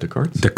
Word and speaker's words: Descartes: How do you pronounce that? Descartes. Okay.